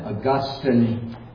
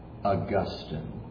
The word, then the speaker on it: Augustine: Let me just stop and say right now, it's pronounced Augustine. Now, there's a city in Florida that is spelled the same way, but it's pronounced differently.